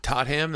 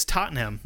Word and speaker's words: Tottenham: Wait. Tot ham? That sounds like a Minnesota hot dish. – It's Tottenham. That's what I said.